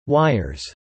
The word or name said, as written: wires